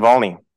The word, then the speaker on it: Navalny: Is that how I pronounce it? – Navalny. – Yes, Navalny.